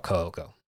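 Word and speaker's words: Kouko: All right, in English, it's K-O-U-K-O. The U is just because the first Ko is extended with the Japanese U, because that's how you elongate a syllable. It's not like an extra U. It's not like a syllable you go to a U sound for. It's just an extension of the first O.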